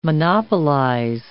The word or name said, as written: monopolize